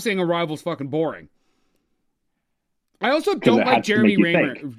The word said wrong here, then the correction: Renner: How do you say his last name? Jeremy Rayner's, Jamie Rames or whatever? – Jeremy Renner.